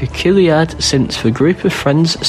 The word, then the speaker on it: chiliad: This week's word is Chiliad. It is spelled C-H-I-L-I-A-D.